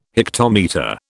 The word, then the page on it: hectometre: British English